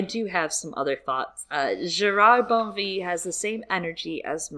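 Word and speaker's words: Gérard Bonneville: I want to make sure the pronunciation by Philip Pullman is known. Gerard Bonnevie. – Oh. – Yes, that is like the pronunciation. – Like Emily in Paris. – That's what I said. Did you see me say that in the chat? By the way, I said that. I literally, yes, during the Blackwell's interview, Pullman confirmed the pronunciation is Gerard Bonnevie. It's very French.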